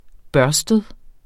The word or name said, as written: børstet